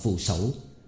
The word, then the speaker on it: phù sẩu: Sẩu ta đọc là thủ, là tay. Phù là nổi trên mặt nước, cũng như phù gia phím trạch, là nhà nổi, nhà bè. Phù thủ đọc là phù sẩu mới đúng điệu, và đó là rương nhẹ xách trên tay.